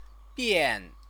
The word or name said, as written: bian